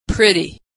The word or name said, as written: pretty